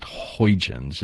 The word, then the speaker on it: Huygens: And he has been doing some heavy work about the Huygens. It's pronounced, I've heard three or four different pronunciations for this probe, but we've been calling it Huygens.